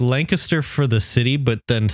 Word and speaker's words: Lancaster: I went to and I have to say this correctly because I learned it, Lancaster, Pennsylvania. Not Lancaster, Lancaster.